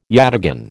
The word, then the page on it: yataghan: American English